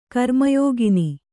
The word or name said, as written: ಕರ್ಮಯೋಗಿನಿ